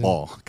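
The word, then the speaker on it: LaMelo Ball: Call them LaMelo Bell. – Still off season. – Ball. I got to say his name right first.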